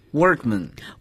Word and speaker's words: workman: 第一个词，工人、工匠，workman。 – workman。 – man 作为名词后缀时，我们不要把它读作 man, 而读作 man, workman, 工人、工匠。